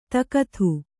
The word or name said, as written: ತಾಕತ್ತು